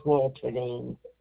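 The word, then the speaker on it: Wolterding: The last name is Walterdine. – Could you spell that last name for me, please? – Oh, sure. W-O-L-T as in Tom, E-R, D as in David, I-N-G.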